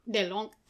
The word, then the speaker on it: DeLonge: So we called a native French speaker and asked her, hey, how would you pronounce this? DeLonge. – DeLonge.